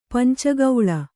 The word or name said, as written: ಪಂಚಕೋಲ